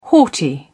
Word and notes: haughty: British English

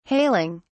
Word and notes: hailing: British English

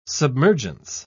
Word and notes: submergence: British English

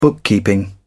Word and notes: bookkeeping: British English

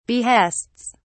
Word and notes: behests: British English

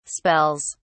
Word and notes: spells: British English

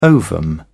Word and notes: ovum: British English

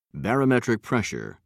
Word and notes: barometric pressure: British English

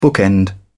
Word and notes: bookend: British English

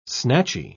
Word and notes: snatchy: British English

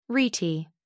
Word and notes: rete: American English

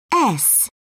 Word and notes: s: British English

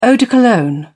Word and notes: eau de cologne: British English

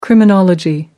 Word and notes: criminology: American English

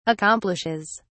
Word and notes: accomplishes: British English

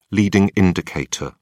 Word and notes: leading indicator: British English

